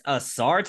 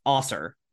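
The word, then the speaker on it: Ausar: And they actually had a note in there that it was Osser and not Asar.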